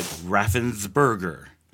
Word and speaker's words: Raffensperger: It's like Raphael, okay, like Raffin, like Raffensperger. – It's like Perger, like isn't it, with a P? – But it's spelled, but it's like Asperger's.